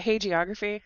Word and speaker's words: hagiography: Ken Burns, one of my criticisms of Ken Burns is that he does a lot of, is it hagiography? Is that how you say it? Yeah, sure.